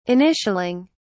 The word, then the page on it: initialing: British English